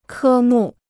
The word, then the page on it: 科目: kē mù